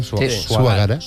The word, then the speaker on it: Suagara: Y además habéis compuesto la que hemos escuchado justo al inicio, que es el nuevo himno del Bilbao Basket, que es Suagara, lo he dicho bien. – Suagara. – Suagara. – Eso es.